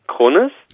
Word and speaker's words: Cronis: Das dann, dann wird's mein, also mein Name, Kronis. Ja wird man auf Griechisch Chronis aussprechen.